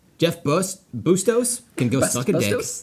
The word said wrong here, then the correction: Bezos: Bustos? Bustos? – Bezos? – I don't know how to pronounce any names. Bezos. There we go.